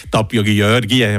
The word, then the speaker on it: Tápiógyörgye: Wie genau spricht man das aus? – Ja, ja das ist schon ganz nach. Also der hat noch da schon entwickelt in die richtige Richtung. Tapio Tapiogiorgie, weißt du, der richtige Ausdruck, den wir mal gelernt haben.